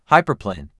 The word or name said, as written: Hyperplane